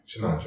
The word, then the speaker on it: chinyanja: and you also speak am i pronouncing this right chinanya chinyanja yeah.